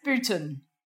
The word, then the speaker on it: Booton: Okay, everyone thinks it's E-N, Booton. – So it's Booton.